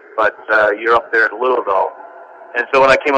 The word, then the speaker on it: Louisville: Louisville, Kentucky. – And well pronounced, brother. It's Louisville.